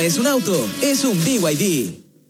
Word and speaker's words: BYD: ¿Los VIV? – ¿VIV? ¿No es VID? – Nada que ver. Se escribe BID, pero se dice BYD.